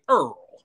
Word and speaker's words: IRRRL: IRRRL. We pronounce it Earl.